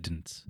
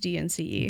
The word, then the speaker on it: DNCE: No, I think it's called Dance or D-N-C-E. I don't actually know. I always say it Dints. – Dints. – Rat tail is a bad answer to that joke. I feel like there's like... – It is... pronounced D-N-C-E. – D-N-C-E. Okay. Yeah. Doesn't, there's like... – It is mean dance, but it is pronounced like an acronym.